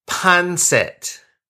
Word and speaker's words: pancit: Did I say it right? – Punzit. – Oh, punzit. – Yes. – Oh, like, oh my God, like a football player, punts it. Did I get that right? – Pancit. – Like, so P-A-N-C-I-T.